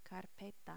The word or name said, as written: carpeta